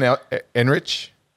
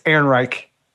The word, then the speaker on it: Ehrenreich: Alden Ehrenreich. Yes. No. Did I say it right? – It's Ehrenreich.